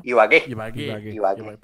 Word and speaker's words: Ibagué: Ibague, ¿no? Ibagué. Perdón la pronunciación, amigos, que se me gasta mi colombiano, entonces.